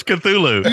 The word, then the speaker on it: Cthulhu: That was Cthulhu.